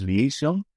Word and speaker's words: Liaison: Depende, porque es una coproducción francesa o británica, con lo cual, si quieres ir por el inglés es Liaison, y si quieres ir por francés, Liaison.